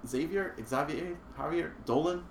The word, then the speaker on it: Xavier Dolan: Xavier Dolan? – Yes.